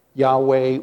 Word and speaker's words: Yahweh: When you come across a text and you see L-O-R-D, L-O-R-D in all caps, it means that it is his personal name. Yahweh or Jehovah is how we pronounce it.